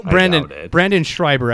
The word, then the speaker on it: Brandon Schreiber: So Brandon Schreiber, I didn't even do your full name.